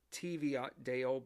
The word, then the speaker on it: Teviotdale: That's spelled T-E-V-I-O-T-D-A-L-E. I would probably have thought it would be Teviotdale, but I'm going to pronounce it as Teviotdale.